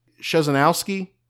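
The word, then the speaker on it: Krzyżanowski: It is pronounced Szesanowski. That's how I pronounce it, but there is some question as to how we would pronounce it.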